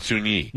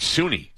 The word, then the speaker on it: Suni: Sun Yi, the biggest takeaway was Sun Yi's name is not Sun Yi. – No. – It's Suni. – Suni. Oh. Yeah, I noticed that whenever they were talking about it. I was like, oh, yeah. I've been saying it wrong this whole time. – The whole world has. We've been calling her Sun Yi, and it's Suni.